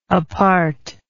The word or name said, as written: apartness